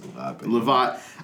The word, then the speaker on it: Lavat: I think it's Lavat.